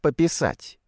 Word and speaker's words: пописать: Прошлым вечером хотел немного пописать. Ударение на последнем слоге.